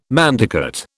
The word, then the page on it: manducate: British English